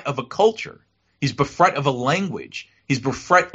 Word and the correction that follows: bereft: ultimately of a religion. You know what I'm saying? – Bereft? – What did I say? Bereft. I'm sorry. I mispronounced it.